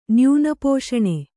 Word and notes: ನ್ಯೂನಪೋಷಣೆ: kannada

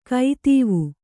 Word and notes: ಕೈತೀವು: kai tīvu

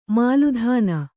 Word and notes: ಮೇಲುತನ: mēlutana

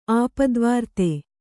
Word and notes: ಆಪ್ತವರ್ತಿ: āptavarti

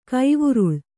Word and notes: ಕೈವುರುಳ್: Kannada

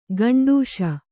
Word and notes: ಗಂಡೂಷೆ: gaṇḍūṣe

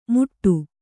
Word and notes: ಮುಟ್ಟು: Kannada

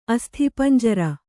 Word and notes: ಅಸ್ಥಿಪಂಜರ: asthipañjara

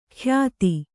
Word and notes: ಖ್ಯಾತಿ: khyāti

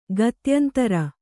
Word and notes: ಗತ್ಯಂತರ: kannada

